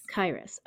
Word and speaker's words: Kirus: K-I-R-U-S. – Kairos, okay.